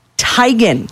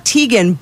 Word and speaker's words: Teigen: Chrissy Teigen. – Yeah. – So it's not Teigen, but what's interesting. So technically, the correct pronunciation is Teigen. – Yeah. – However, she prefers Teigen.